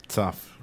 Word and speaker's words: Tzav: La Parachá, parashá, perdón, Parachá para acá, Saf. Así se, pronuncia, Moré. Saf, correcto.